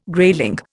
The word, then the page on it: grayling: Indian